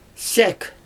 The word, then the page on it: sʲæk: Xyzyl